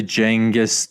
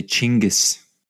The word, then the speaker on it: Chinggis: Don't say Genghis. – It's Genghis, right? – So the American English pronunciation would normally be Genghis, but that is... So if you want to be proper Mongolian, you would say Chinggis.